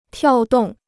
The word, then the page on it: 跳动: tiào dòng